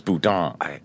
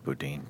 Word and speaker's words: boudin: He keeps saying Bowden balls. – But I spent like 20 minutes looking up different pronunciation keys, and the vast majority thought it was boudin. – I heard the waitress say it, so I'm going with boudin.